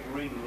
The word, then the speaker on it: wring: Because take ring, like ringing out the clothes, mm-hmm. W-R-I-N-G, and then mm-hmm. the ring at the double time. – That's true. – It's pronounced the same, but spelled differently.